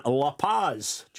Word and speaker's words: La Paz: Well, in La Paz, do you pronounce this? – You do. Yeah.